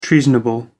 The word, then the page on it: treasonable: American English